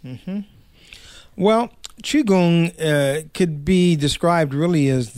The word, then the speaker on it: Qigong: And we've talked a lot about It's not Qigong. How do you say it? – Mm-hmm. Qigong. – Qigong.